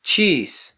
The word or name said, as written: chis